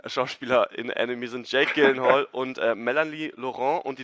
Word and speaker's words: Gyllenhaal: Ja, also ich habe da eine relativ vertrauenswürdige Quelle in Form von, ja, Gyllenhaal, als er seines Zeichens zu Gast bei Conan O'Brien war.